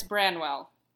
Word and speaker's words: Branwell: Is it Branwell or Branwell? Because I felt like I was saying his name wrong. – It's Branwell. B-R-A-N.